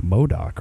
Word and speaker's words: MODOK: So then this is Marvel's MODOK. I think you just say it like MODOK, right? – Yeah, that's MODOK. How you say it.